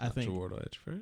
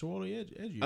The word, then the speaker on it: Chiwetel Ejiofor: That's Chiwetel Ejiofor, is it? – Yeah, it's Chiwetel Ejiofor. – Oh, that's how you pronounce that.